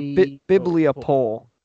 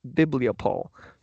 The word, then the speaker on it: bibliopole: It is bibliopole, I think is how you say it. Let me Yeah, biblio poll. So B-I-B-L-I-O, biblio, and then poll, P-O-L-E, biblio poll.